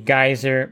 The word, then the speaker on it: Gysar: was the geyser by Hasa, and that is spelled a little bit differently than you would think hearing the word on the air. You actually will spell it G-Y-S-A-R, and that's the Tile Bright.